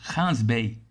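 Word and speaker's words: Gansbaai: Tudo acontece na cidade ao lado, Hans Bay, que se escreve Gans Bay com G de Geraldo, mas essa letra tem som de R em africâns.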